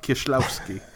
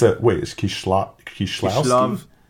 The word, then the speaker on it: Kieślowski: Travis, you're going to tell me it's Krzysztof, right? – That's how someone told me it's pronounced, Krzysztof Kieślowski. – It's Kieślowski? It's Kieślowski, though, right? Yeah, there you go. – Kieślowski. Krzysztof Kieślowski.